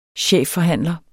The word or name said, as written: chefforhandler